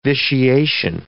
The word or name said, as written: vitiation